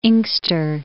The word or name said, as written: Inkster